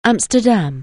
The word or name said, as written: Amsterdam